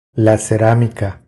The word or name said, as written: La ceramica